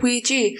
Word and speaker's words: Ouija: I'm really overthinking how you say it. Ouija, I say, and on the film they said Ouija.